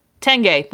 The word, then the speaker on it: Tanguay: Tengay.